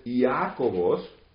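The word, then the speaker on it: Iacobus: Entonces dice cómo se pronunciaba antes Iacobos en latín, Iacobos. Entonces sería ese énfasis en la primera palabra suena más como Iago de Santiago.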